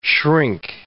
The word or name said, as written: shrink